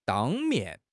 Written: 当面

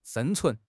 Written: 生存

